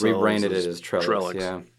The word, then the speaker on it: Trellix: Trellix? – Trellix.